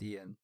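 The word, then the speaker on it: Iain: He spells his name funky. It's I-A-I-N. – Oh. – He's Scottish. – Oh, that makes sense. – Ian, but it's pronounced Ian.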